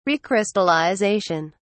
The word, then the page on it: recrystallization: British English